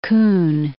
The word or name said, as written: Kun